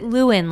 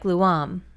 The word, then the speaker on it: Leeuwen: I pronounce it Leeuwen, like Luam. Luam. Leeuwen.